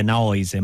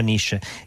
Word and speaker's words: Naoise: Segnalo per chi fosse interessato al libro che il, il nome è irlandese, noi italiani lo pronunceremmo probabilmente Naoise, ma si pronuncia Nish.